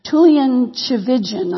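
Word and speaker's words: Tullian Tchividjian: His name is, I'm going to blow this name, Tulian Chivijan, I believe is how you say it.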